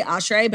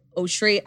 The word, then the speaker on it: Oshry: I say Oshry, but I think it's like a Jewish last name. It's technically Oshry. I don't like to say it like that.